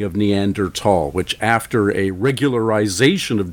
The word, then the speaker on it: Neanderthal: You talk to most paleoanthropologists today, and you look at most of the literature today, and everybody has gone back to Neanderthal, the T-H-A-L spelling, and most pronounce it Neanderthal these days.